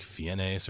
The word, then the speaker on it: Fiennes: And their last name is spelled like F-I-E-N-N-E-S, so it could be like Fiennes or something like that, but it's Fiennes.